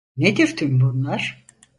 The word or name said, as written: bunlar